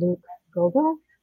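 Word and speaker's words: Goebel: And it's by a writer named Luke Gogol. Am I pronouncing his name correctly? – Yeah. – Okay.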